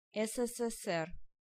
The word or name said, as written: USSR